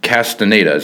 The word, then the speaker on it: Castaneda: I've got John Elder and Milton Castaneda.